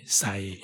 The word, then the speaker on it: Sai: Sai it come from Laotian language or Isan. It it from Chaya. They could not they pronounce cha to be sa become Sai.